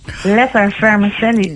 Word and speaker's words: lettervermicelli: Lettervermicelli. Ik weet nooit waar de klemtoon ligt. Maar... – Ja, ligt er eigenlijk wel een klemtoon op. Het is gewoon lettervermicelli.